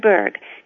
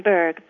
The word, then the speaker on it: Burg: hello, my name is Anne Berg. Quite a simple name to pronounce, but not always as simple to spell. Whenever anyone asks me my name, I always need to say Anne Berg, B-U-R-G. Oh, and that's Anne, A-N-N, with no E at the end, unless you want to include my middle initial, which is E, for Elizabeth.